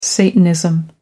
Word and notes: satanism: American English

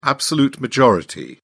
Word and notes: absolute majority: British English

